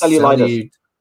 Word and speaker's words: cellulitis: I think it's cellulitis.